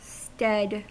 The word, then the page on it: stead: American English